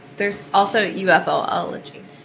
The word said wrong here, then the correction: ufology: Ufology is how you say it.